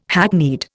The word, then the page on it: hackneyed: Indian